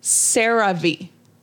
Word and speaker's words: CeraVe: It is pronounced CeraVe. – That's what I said, isn't it? CeraVe? – I think you did.